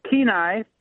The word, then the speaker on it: Kenai: Did I say that right? – You came close. It's Kenai.